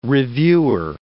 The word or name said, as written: reviewer